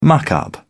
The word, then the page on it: muck up: British English